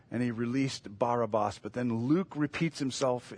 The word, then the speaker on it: Barabbas: Barabbas, it's Barabbas, as we've come to say, but it should be pronounced Barabbas.